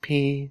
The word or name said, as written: pi